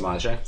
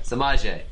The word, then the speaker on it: Samaje: Because he's being drafted around the same time as, how do you say his name? I keep forgetting. – Samaje. – Samaje.